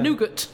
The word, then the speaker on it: nougat: Nougat. – Nougat. – Nugget. – I've never actually said that word out loud, so I don't know how to say it. You're supposed to say nougat.